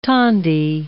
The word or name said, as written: tondi